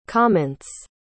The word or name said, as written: comments